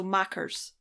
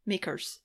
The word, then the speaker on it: Makars: Nah, it's spelt M A K A R S. So Mackers, Makers, Mackers. I think.